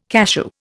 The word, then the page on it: cashew: Indian